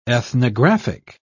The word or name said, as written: ethnographic